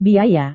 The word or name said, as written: biaya